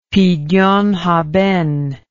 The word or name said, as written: pidyon haben